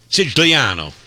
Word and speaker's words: Sicilia: Siciliano.